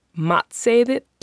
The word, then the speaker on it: matseðill: A menu in Icelandic is matseðill.